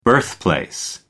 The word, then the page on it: birthplace: American English